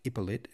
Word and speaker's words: Ippolit: That's pronounced Ippolit, and sometimes in some versions spelt I-P-P-O-L-I-T. So if you're wondering if they're the same person, Hippolyte and Ippolit, yes, they are.